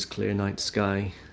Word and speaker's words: ClearNiteSky: I may also be known as Clear Night Sky, which is spelled C-L-E-A-R-N-I-T-E-S-K-Y.